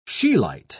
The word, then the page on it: scheelite: British English